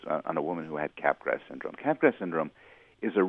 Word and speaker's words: Capgras: I'm not sure how it's pronounced. Capgras? – I think it's Capgras syndrome. – Yeah, Capgras syndrome.